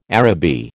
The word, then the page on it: Araby: American English